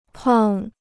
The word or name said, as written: 胖